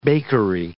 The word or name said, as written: bakery